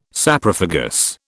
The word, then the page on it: Saprophagous: British English